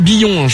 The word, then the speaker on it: Billom: Donc c'est Billon, je me suis fait engueuler par Maureen, désolé d'avoir mal prononcé. – Ouais, c'est ça, c'est Billon.